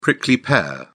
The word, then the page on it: prickly pear: British English